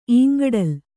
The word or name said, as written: ಇಂಗಡಲು